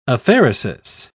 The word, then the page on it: apheresis: British English